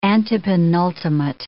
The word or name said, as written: antepenultimate